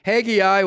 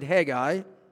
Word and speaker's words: Haggai: And so in this short book of only two chapters, totaling 38 verses, Haggai, or you can pronounce it Haggai,